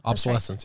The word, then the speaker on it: obsolescence: And that term, planned obsolescence. Am I saying that right? Yes. I feel like I say it wrong That's every right. single time. – Obsolescence, right. – yeah. – Obsolescence. – Obsolescence. Yes.